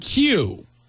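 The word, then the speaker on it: queue: The word Q, Q-U-E-U-E, is pronounced exactly the same way if you remove four of its five letters. Yeah, if you do that, you're left with the letter Q, which is pronounced the exact same way you pronounce the word Q, Q-U-E-U-E.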